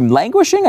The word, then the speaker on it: languishing: Linguishing. – Linguishing. – I believe she languishing. I think is what she was going for.